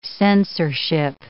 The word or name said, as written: censorship